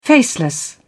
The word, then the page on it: faceless: British English